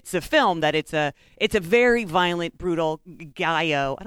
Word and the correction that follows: giallo: Giallo? That's how you pronounce it? Okay. I guess it's giallo. – Okay. Which is, it's Italian for yellow, so I guess it makes sense. It kind of sounds like yellow.